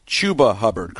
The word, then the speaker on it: Chuba Hubbard: The pronunciation is Chuba Hubbard, correct? Chuba Hubbard is a star running back at Oklahoma State.